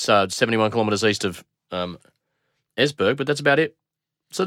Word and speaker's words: Esbjerg: And Tim was from Esbjerg. E-S-B-J-E-R-G. Esbjerg. – I see from your pronunciation you know where that is.